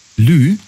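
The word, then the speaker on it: lieu: Lü. Also Lü, das schreibt man, also L-I-E und dann U.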